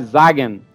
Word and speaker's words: Sagen: Então o termo, o jeito certo de falar é Sagen.